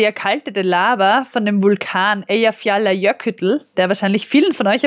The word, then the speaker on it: Eyjafjallajökull: Nein, Eyjafjallajökull, glaube ich.